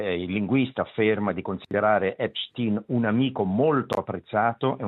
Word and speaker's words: Epstein: o Epstein, pronunciamolo all'americana, Epstein, per traffico di minorenni, ragazze e ragazze, insomma dei minorenni.